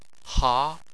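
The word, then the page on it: ha2: tone 2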